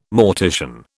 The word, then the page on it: mortician: British English